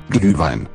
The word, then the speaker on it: Glühwein: Glühwein.